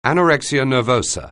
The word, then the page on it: anorexia nervosa: anglais